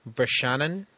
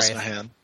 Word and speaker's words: Brosnahan: Uh, so I got uh, Miriam Midge Maisel, uh, played by Rachel Brosnahan. I'm sorry. Brosnahan.